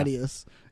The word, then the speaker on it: Attias: Attias. Oh. I always say it wrong. Yeah. Attias.